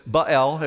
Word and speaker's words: Baal: Now, Baal is Baal, if you want to pronounce it the correct way, in the Old Testament.